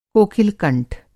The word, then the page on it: कोकिल-कंठ: Hindi